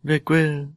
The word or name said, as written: veguil